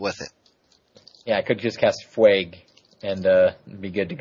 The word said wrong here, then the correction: Fugue: I believe it's pronounced Fugue.